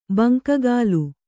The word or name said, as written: ಬಂಕಗಾಲು